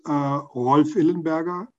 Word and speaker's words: Rolf Illenberger: So my name is Rolf Illenberger, as you would properly pronounce this in German, but it's perfectly pronunciation is Rolf.